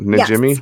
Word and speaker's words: Najimy: I don't Najimy.